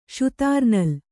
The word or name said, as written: ಷುತಾರ್‍ನಲ್